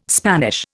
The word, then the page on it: Spanish: Indian